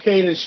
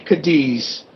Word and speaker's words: Cadiz: Cadiz Street was where the apartments were. Or as the British say, Cadiz.